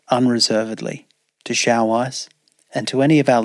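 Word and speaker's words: Xiaoice: I want to go on record and acknowledge that during the recording of this podcast, I described one of the platforms offering AI companions as Xiaosi, when in fact it is pronounced Xiao Ice.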